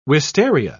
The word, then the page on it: wistaria: British English